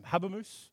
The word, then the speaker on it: Habermas: Habermas.